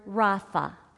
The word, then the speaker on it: Rapha: Let me tell you, that word healed is the word rapha.